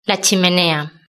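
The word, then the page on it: la chimenea: Spanish (Spain)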